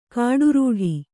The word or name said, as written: ಖೊಟ್ಟಿರೊಟ್ಟಿ